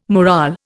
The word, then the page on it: morale: Indian